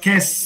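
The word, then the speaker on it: Quai: Você sabe, eu fui num, eu fui num evento em Paris que se chama Quer eh, se escreve Kwai, mas se chama Kes né?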